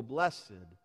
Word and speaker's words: blessed: But the translation blessed, it's a little bit soft and sentimental, especially when we pronounce it with that fanciful accent on the second syllable, blessed.